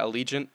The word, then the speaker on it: Allegiant: Is it pronounced Allegiant? Yeah, Allegiant.